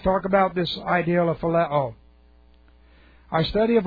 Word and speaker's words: phileo: This is phileo. Let's take a look. Phileo. P-H-I-L-E, long O. Phileo.